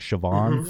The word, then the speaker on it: Siobhan: Anyway, S-I-O-B-H-A-N. I think that B is also... sort of a softer V sound, maybe. So it's like Siobhan. – Mm-hmm. Right. – So yeah. Siobhan. Right. Yeah. – Exactly. Because you've got the S I is Sh, because mm-hmm. of the S is before the thing. And a BH or an MH are pronounced kind of like a V.